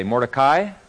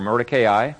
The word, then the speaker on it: Mordecai: And by the way, you can pronounce it either way, Mordecai or Mordecai. Mordecai is the Hebrew way, and but in the pronunciation dictionaries, you'll see it pronounced either way.